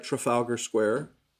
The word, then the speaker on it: Trafalgar Square: Trafalgar Square.